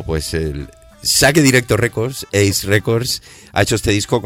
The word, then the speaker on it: Ace: Es una colección de 26 canciones que ha editado el sello Ace Records, que se escribe A-C-E Records, AC Records, sin H, para aquellos que el inglés no lo tengas. Ace, sí, exacto, Ace, me está indicando, exacto, Ace, ¿qué es exactamente? – Pues un saque directo que no se devuelve.